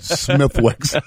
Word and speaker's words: Smithwick's: Just remember to pronounce it Smithwick's.